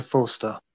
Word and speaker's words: Forster: Say it right. – Fraser Forster. – It's a long R.